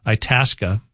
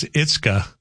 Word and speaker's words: Itasca: Texter saying a new Valley News live anchor, pronounced Itasca, as Itzka on the news last week.